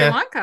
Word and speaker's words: Sri Lanka: Sri Lanka.